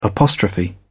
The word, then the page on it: apostrophe: British English